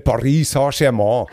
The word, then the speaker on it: Paris Saint-Germain: Man muss es eigentlich wie Beckenbauer aussprechen, ne? – Paris Saint-Germain.